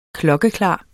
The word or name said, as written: klokkeklar